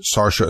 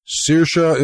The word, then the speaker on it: Saoirse: Anyway, he pronounced it Sarsha as well. Saoirse is also correct, of course, in whatever dialect applies, and I've also heard it pronounced Saoirse.